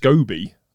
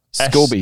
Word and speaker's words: Scobie: Well, I think it's pronounced Scobie. – Scobie. – S-C-O-B-I-E. Like Scooby-Doo, but with 1-0.